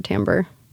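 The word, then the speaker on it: Timbre: I like the band Timber Timber, even though I've been told it's pronounced Timber Tambor.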